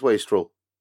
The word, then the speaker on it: wastrel: Is it wastrel or wastrel? – I think it's wastrel. – See, I've always pronounced it wastrel. – Yeah, this is probably why we are not, like, incredibly successful spy thriller writers. – Yeah, you're right. It is wastrel. Because it's taken from the word waste.